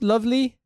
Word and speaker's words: Lvly: Look, I think it's pronounced lovely. It's L-V-L-Y. – Right? L-V-L-Y. – Yeah. – Yeah, that feels like lovely.